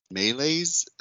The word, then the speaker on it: malaise: Elder Holland told the young adults, we recognize the understandable malaise. Is that how you say it? M-A-L. I feel dumb. M-A-L-A-I-S-E. Malaise.